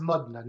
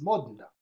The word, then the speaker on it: Modena: It's pronounced Modena, not Modena. The city. The city is a city in Italy today. It's Modena.